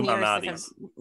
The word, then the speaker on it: Lou Malnati's: It's been Lou Malnati's.